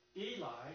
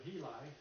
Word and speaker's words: Heli: But if we put this all together, Eli, or Heli, how we pronounce it, was the father of Mary, the mother of Jesus.